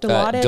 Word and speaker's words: Dilaudid: Dilaudid? Is that how you say it? Dilaudid? – Dilaudid. – Dilaudid. Yeah. Okay.